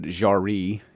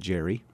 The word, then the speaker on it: Jarry: It's a translation from Alfred Jarry. Is the French pronunciation, I believe. I call him Alfred Jerry. – There you go. – The play is Ubu Wa, or Ubu Roy, R-O-I, and we're doing a translation by a guy out of Canada named David Copeland.